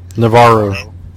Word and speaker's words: Navarro: Navarro. – Navarro Imperial Safe House 1/6 scale diorama.